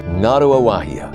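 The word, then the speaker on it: Ngāruawāhia: Ngarua Wahia, spelled N-G-A-R-U-A-W-A-H-I-A. To spell it like a local, all you have to remember is, nine girls are running under a wharf, and here I am.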